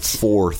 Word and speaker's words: fourth: Remember, guys, you usually want to pronounce the TH there. – Right. – Fourth. – Fourth. – Not super easy, but just keep trying.